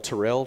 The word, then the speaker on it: Tirrell: My name is spelled Terrell, T-I-R-R-E-L-L, Payton, P-A-Y-T-O-N.